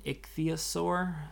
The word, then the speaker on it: ichthyosaur: an ichthyosaur. Um, I think I'm saying that right. Yeah.